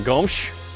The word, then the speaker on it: Gomes: Thank you for saying Gomes. That's how she would pronounce it.